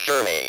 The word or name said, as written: journey